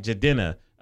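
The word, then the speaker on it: Jidenna: Issa Rae's networking platform with people like Jadenna, that's how you say his name, right? – I'm a classic man. – Jadenna, yeah.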